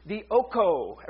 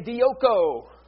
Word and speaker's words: dioko: And the word for today, I even wrote it out phonetically, is dioko. Everybody say dioko. It sounds like, didn't she marry John Lennon or something like that? Yoko Ono, something like, no, it's Greek, dioko, which simply means to make someone run away or cause someone to flee.